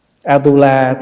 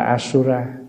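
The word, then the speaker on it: A-tu-la: atula tiếng phạn đọc là asura cho nên mình chỉ âm lại là atula thôi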